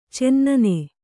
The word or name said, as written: ಚೆನ್ನನೆ